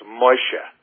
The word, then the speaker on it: Moshe: Actually, it's pronounced Moshe, but the English version is Moses.